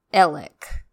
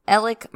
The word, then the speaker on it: Alex: Also, Alex is pronounced Elik. That's just the way that it is. That's how all of my sources and people that know him say it. So it's Elik Murdoch.